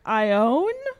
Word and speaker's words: Ione: It is spelled I-O-N-E. Is that... – Ewan McGregor? I can't do it. – Eon? Ione?